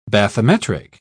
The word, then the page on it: bathymetric: British English